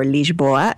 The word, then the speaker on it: Lisboa: We were in Lisbon, or Lisboa as they pronounce it.